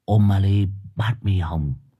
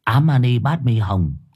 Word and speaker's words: Om Mani Padme Hum: Mày cứ đọc là Om Mani Padme Hum, hay là thôi đọc mẹ tiếng Việt Ám Mani Padme Hum, nhưng mà trong đầu phải niệm thành tâm vào nghe chưa.